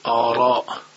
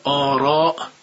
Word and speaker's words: آراء: اما نکته‌ای که باز در مورد حرف را عرض میکنم، ما در تلفظ دو کلمه در زبان فارسی، یکی عرابه یکی آرا، ما دو تا را ادا میکنیم. هنگام گفتن کلمه عرابه دقیقا از نقطه‌ای که حرف را ترقیق میشه حرف را رو تلفظ میکنیم. اما در موقع گفتن حرف کلمه آراء دیگه از نقطه‌ای ناخودآگاه حرف را رو تلفظ میکنیم که نقطه ایست که از اونجا میشه تفخیم کرد.